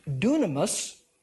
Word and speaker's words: dunamis: The Greek word for power here is pronounced dunamis, and it gives us our English word dynamite.